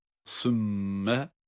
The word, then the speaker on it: ثُمَّ: ثُمَّ شَقَقْنَ الْاَرْضَ derken ثُمَّ, bir önceki dersimizde biraz detaylı durmuştuk üzerinde, sa harfi peltek olarak okunuyor ve mim şeddeli olması itibariyle ona bağlıyoruz. Sümme, sonra şakak, şakak, iki tane kaf harfi arka arkaya, birisi harekeli diğeri cezimli.